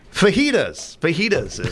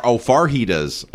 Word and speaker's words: fajitas: Fajitas is on this list. So fajitas, but they're saying like how people will say it with an R, fajitas or farjitas.